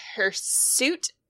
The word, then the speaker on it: hirsute: The fairy tale is based off the hirsute, H-I-R-S-U-T-E. Am I saying that right? – Oh. Yep.